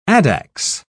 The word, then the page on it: addax: British English